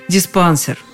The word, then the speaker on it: диспансер: Повторять неправильный вариант еще раз я не стану, а повторю-ка лучше так, как предлагают словари. «Диспансер».